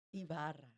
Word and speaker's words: Ibarra: And without further ado, I'd like to introduce Fernanda, and your last name is pronounced Ibarra. – Ibarra. See, I was going to say it without that marvelous accent.